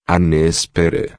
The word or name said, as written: nespera